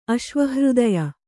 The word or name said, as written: ಅಶ್ವಹೃದಯ